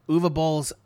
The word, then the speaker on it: Uwe Boll: My guess is this might be Uwe Boll's, which is how I'm supposed to pronounce his name. I was told Uwe Boll. – Sure. – Uwe Boll's attempt to add some of the historical context of the period, because racism against Irish people was a thing.